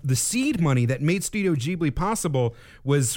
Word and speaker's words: Ghibli: Also the name of an Italian aircraft, also called that was called the Ghibli with a hard G. The studio is Ghibli with a soft G.